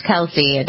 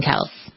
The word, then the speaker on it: Kelce: Do you say Kelce or Kelce? – I say Kelce, but I don't know if that's right or not. – Okay, so everybody else does too. Jason and Travis Kelce, that's how it's always reported.